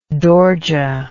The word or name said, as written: dorje